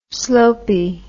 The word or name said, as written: slopey